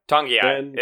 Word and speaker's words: Togiai: Uh, Noah Togiai. – Tongiai is like an N there. Tong- it's like Tongiai. Oh, Tongiai. Yeah. Um, silent N, classic.